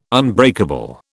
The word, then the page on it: unbreakable: British English